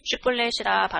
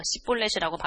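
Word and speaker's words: 쉿볼렛이라 (שִׁבֹּלֶת): 에브라임 사람들이 그렇게 바로 말하지 못하고 시뿔렛이라고 발음하면 에브라임 사람들은 쉬를 발음할 수 없었습니다.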